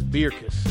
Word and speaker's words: Beerkus: Beerkus is the Flemish pronunciation, but, you asked. I don't normally correct folks. – I like Beerkus.